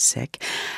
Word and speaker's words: Sikh: He's Sikh or Sikh, as some people pronounce it. He pronounces it Sikh.